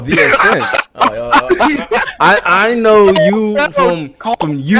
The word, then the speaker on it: The Ascent: Did you play the Accent? I've been heavy into Accent lately. – Did you say accent? – The ascent. The ascent. – Like to rise.